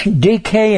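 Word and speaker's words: dike: D K is the pronounced DK.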